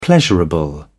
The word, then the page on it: pleasurable: British English